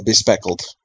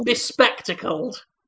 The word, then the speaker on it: bespectacled: The bespeckled, or the spectacled? How do you say you got glasses on? – Bespectacled. – Bespectacled, thank you. Bespeckled is freckles, I think.